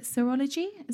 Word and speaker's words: serology: I think is it serology? Is that how you say it properly? – Yeah. – How, yeah, yeah, that's correct.